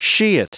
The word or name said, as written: shit